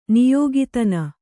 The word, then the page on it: ನಾಯಿಕೆತನ: nāyiketana